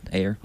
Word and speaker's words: Eyre: I don't know how to pronounce Ayer. E-Y-R-E. – Yeah, Ayer.